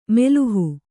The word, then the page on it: ಮೆಲುಹು: Kannada